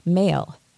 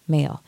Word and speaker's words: M(h)aol: And I thought, as it was playing, I was looking up, how do I pronounce M bracket H-A-O-L? So this is an Irish word, and the H was put in brackets to let people know that you don't pronounce H, and it's actually male is the way you pronounce that. So M-H-A-O-L, male.